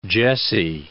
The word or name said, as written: Jesse